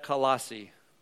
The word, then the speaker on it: Colossae: Colossae. This is funny, it was like C-O-L-O-S-S-A-E. I'm like, how do you pronounce that? You just take the A off. Colossae.